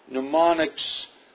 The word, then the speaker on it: mnemonics: Mnemonics is one of those curious words that begins with a silent M. M-N-E-M-O-N-I-C-S. Mnemonics.